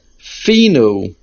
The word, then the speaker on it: finu: However, in davening in Nishmas, the word is finu, and the main accent is on the fe.